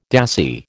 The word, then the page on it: dassie: American English